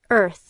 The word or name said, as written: earth